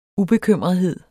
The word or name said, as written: ubekymrethed